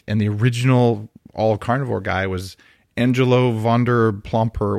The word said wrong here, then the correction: Vonderplanitz: whatever. I, I, can never say his name right, but you know his name. – Vonderplanitz. – Vonderplanitz. Vonder, Vonderplanitz.